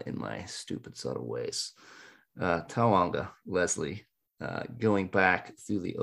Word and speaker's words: Taonga: Three syllables, as you've got that down. First one's ah, second one's oh. – Okay, Taonga, Leslie.